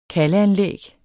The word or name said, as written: kaldesystem